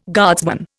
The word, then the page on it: guardsman: Indian